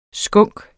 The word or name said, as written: skunk